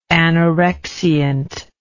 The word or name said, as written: anorexiant